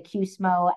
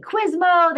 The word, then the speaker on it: QSMO: I will tell you that in the spaces where I have most insight, which would be the QSMO, and I love that you pronounced that correctly. Most people don't. They say QSMO. There's all sorts of versions.